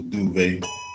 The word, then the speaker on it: Duvay: Yeah, it's almost like two syllables, Duvay.